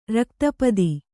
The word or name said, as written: ರಕ್ತಪಿತ್ತ